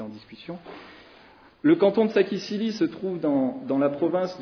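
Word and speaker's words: Saquisilí: Sakisili, ça s'écrit S-A-Q-U-I-S-I-L-I.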